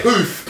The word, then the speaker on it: Puth: Poof? I don't know how to say it. Poof. – Poof. Sorry.